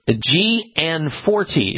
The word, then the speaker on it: Gianforte: Montana's Governor Greg Gianforte, or Gianforte is how you pronounce his last name.